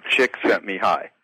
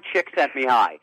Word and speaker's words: Csikszentmihalyi: Well, you taught me how to pronounce it, Csikszentmihalyi. – Yes, you're right. – Okay. – Mihai Csikszentmihalyi.